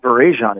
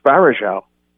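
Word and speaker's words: Varejão: He said, it's not Barajan, it's Barajal. I'm like, are you sure? He's like, yeah. I said, okay, well, so when we play Cleveland, I'm going to say Barajal, and I won't get laughed at.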